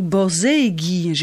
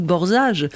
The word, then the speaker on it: Borzage: Et aujourd'hui, eh bien, il va s'agir de Monsieur Franck Borzaghi. J'ai appris qu'on ne prononce pas du tout Franck Borzage. Mais non, c'est trop franco-français. C'est Borzaghi. Voilà, d'origine italienne, c'est ça? – Oui, merci.